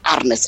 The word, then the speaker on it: Ernest: Quizás en catalán Ernest, porque recordemos que es de Cataluña, se pronuncia como una especie de A, Arnes.